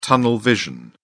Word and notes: tunnel vision: British English

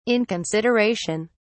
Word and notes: inconsideration: British English

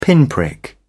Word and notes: pinprick: British English

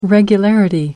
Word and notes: regularity: British English

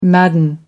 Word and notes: madden: American English

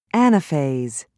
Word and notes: anaphase: British English

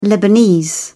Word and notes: lebanese: British English

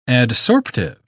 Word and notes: adsorptive: British English

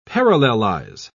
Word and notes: parallelize: British English

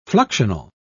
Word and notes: fluxional: British English

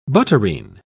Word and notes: butterine: British English

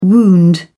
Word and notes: wound: British English

